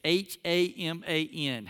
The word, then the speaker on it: Haman: So when you hear his name, it's spelled it's H A M A N, Haman.